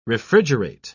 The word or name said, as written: refrigerate